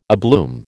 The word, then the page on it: abloom: American English